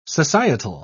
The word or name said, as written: societal